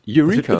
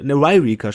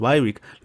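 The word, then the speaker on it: Yreka: Eureka. – Das ist YR. Eureka. Ne, Ryrica sprechen die das. Ryrica. YRKA. – Ah. – Ein Y, dann direkt ein R dahinter. Das ist Ryrica. – Ryrica.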